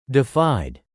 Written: defied

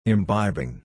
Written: imbibing

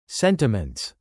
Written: sentiments

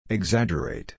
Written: exaggerate

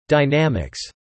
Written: dynamics

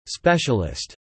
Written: specialist

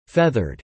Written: feathered

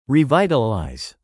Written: revitalize

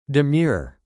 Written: demure

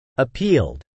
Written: appealed

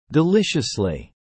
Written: deliciously

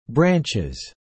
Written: branches

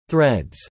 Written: threads

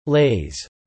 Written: laze

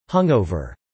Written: hungover

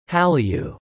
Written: Hallyu

